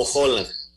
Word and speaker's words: Holan: Mira, Toño, es más, yo una vez te digo, nuestros amigos argentinos lo pronuncian como Holland.